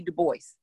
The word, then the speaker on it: Du Bois: Oh, for y'all that don't know, it's not W.E.B. Du Bois. It's Dubois. We should say his name right.